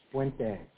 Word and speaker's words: Puente: How do you pronounce his last name? – Puente. – Puentes, okay. – Puente. Tito Puente.